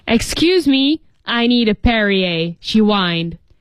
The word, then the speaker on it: Perrier: 注意读音叫 Perrier.